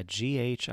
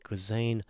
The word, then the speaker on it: Ghislaine: Google's got audio correct for me. Ghislaine Maxwell, yeah, Ghislaine, Ghislaine Maxwell.